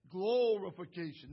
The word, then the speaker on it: glorification: Glorification, not glory. It's not going to be glory. Dr. John Brooke always said, make that a long O, Mr. Skyver. Glow.